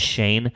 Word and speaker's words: Schoen: It looks like it should be Joe Schoen, but I believe it's pronounced Shane.